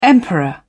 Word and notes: emperor: British English

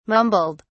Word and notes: mumbled: British English

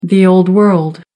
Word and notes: old-world: American English

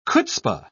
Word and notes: chutzpa: British English

